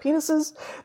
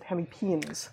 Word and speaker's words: hemipenes: And so a synapomorphy, a diagnostic, a shared derived character of squamates, which is, again, lizards and snakes, is having hemipenes, which is two eversible penes, which is really how it's pronounced. I would have said penises, but as a herpetologist, that is to say someone who studies the creepy crawlies that include the amphibians and reptiles, I know that you're supposed to pronounce it hemipenes.